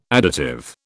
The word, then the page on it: additive: British English